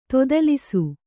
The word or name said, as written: ತೊದಲಿಸು